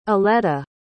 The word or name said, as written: aletta